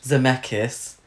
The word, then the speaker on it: Zemeckis: Zemeckis.